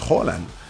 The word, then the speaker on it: Haaland: Porque cumplieron, y impresionante, lo de Kylian Mbappé, lo de Erling Haaland, que en realidad la pronunciación es Haaland.